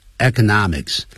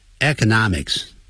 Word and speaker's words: economics: 等于 about politics 有关政治啊，and economics 这有两个发音，economics 啊，另外一个是念成 economics 啊，两个都可以，economics 经济学，或者是长音长元音 economics。